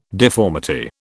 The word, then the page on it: deformity: British English